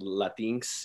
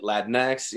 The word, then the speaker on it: Latinx: Some people have say it Latinx, you know, others say it Latinx. You know, I prefer Latinx just because it's easier to say for me, you know?